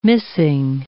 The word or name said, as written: missing